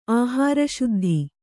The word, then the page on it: ಆಹಾರಶುದ್ಧಿ: āhāraśuddhi